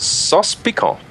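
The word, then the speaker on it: sauce piquante: How do you say that again? Sauce piquant? – Sauce piquant. – That's cool. – It's kind of spelled like sauce picante, but it's Cajun instead of Mexican.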